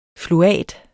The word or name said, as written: fluat